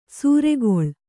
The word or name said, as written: ಸೂರೆಗೊಳ್